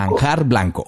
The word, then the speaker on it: manjar blanco: Es que a veces lo pronunciamos así rápido y como que se nos confunde. – Sí. Toda la vida hemos dicho marja blanco, sí, como al revés.